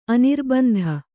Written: ಅನಿರ್ಬಂಧ

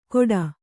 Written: ಘೋಟಾ

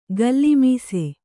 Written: ಗಲ್ಲಿಮೀಸೆ

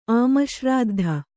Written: ಆಮಶ್ರಾದ್ಧ

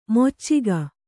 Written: ಮೊಚ್ಚಿಗ

